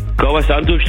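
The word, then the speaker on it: Kauã Santos: Kauer Santos, der hast du gut ausgesprochen. Der ist super.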